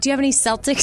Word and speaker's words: Celtic: That's Celtic, like the Boston Celtics. – It's Celtic.